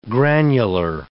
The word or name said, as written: granular